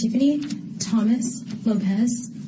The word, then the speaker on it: Tiffany Thomas Lopez: My name is Tiffany Thomas Lopez, and it's spelled TIFFAYNYTHOMASLOPEZ.